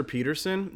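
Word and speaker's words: Petersen: It's on Google Plus, but if you go to my Facebook page, Producer Peterson.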